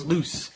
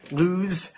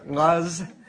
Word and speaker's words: Luz: If you're Spanish background, it's loose. But this is not Spanish, obviously. So I'm going to say luz or luz.